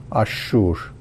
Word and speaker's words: Ashur: Ash, being the solar alif lam of Aramaic, it must be Al-Shur, which according to the Arabic grammar, it is pronounced like Ashur.